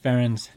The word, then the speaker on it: Ferencz: Yeah, it's pronounced Ferencz. And one of the things that a lot of people don't know is Ferencz, the brand, is actually the phonetic pronunciation of my last name.